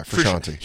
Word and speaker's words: Frusciante: Yeah, Frusciante.